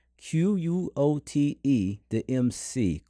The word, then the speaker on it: Quote the MC: Let me say it right. Q U O T E the MC.